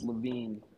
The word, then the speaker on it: Levin: My last name is Levin, spelled without the E, L-E-V-I-N.